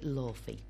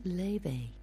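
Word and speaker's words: Laufey: I've been pronouncing it Lofi because that's how you read it, you know, that's how it's spelt, Lofi. But actually, it's an Icelandic name and it's pronounced Levi.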